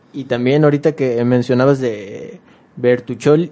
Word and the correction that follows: Bertolucci: Bertu, ¿cómo, se pronuncia? Bertolucci. Bertolucci,